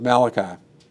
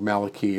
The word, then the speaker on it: Malachi: And I just suspect that he says, you know, what you're reading ends with the book of Malachi, or Malachi if you want to pronounce it correctly.